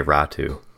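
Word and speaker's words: Räty: Is it pronounced Ratu? Because it's spelled Ratty. – It is, but everywhere I've seen, I've heard them say Ratu.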